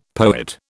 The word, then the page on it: poet: British English